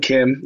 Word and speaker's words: Kim: My socials are, so my name is Young Kim, but I spell it the normal way, K-I-M.